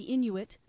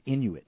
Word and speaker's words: Inuit: Yes, they've done some studies recently that show in the frozen Arctic, the native peoples who live in this region, the Inuit. – That's how you pronounce it, Inuit. – Inuit. – Not Inui or...